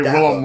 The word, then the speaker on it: Willem: You gotta say Willem like you can't. It's W-I-L-L-E-M. – In my book, it's William. – Let me see. – Well, that's because you can't fucking read, then. – I'm sorry. – It's spelled W-I-L-L-E-M. Oh! There's no I.